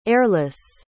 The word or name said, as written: eirlys